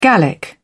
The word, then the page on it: gallic: British English